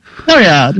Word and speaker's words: Floriade: Floriade.